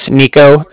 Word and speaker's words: Niko: So yeah, like I said, my name's Nico.